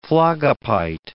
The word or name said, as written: phlogopite